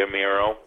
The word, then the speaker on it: Amirault: And I think you pronounce it the French way, which is great. It should be Amirault.